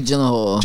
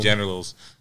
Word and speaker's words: Gyllenhaal: Jake Gyllenhaal. – Jake Gyllenhaal. – Fantastic movie. – Shady produced. – Jake Generals.